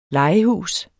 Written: legehus

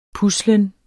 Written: puslen

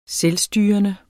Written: selvstyrende

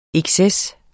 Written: exces

